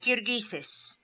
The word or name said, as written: Kirghizes